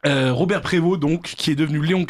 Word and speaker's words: Prévost: Prévost, n'est-ce pas ? – Prévost. – Qu'on peut prononcer Prévost à la française.